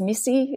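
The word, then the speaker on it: MSSI: Look, I worked at RMIT University in Melbourne for over 20 years, and then I just moved to, we call that MISSI, the acronym is MISSI, is how we pronounce it,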